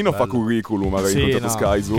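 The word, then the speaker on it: Skyzoo: All'inizio ho sbagliato la pronuncia. Ho detto, ma tu sei schizo? – No. – E lui mi fa, Sky Zoo.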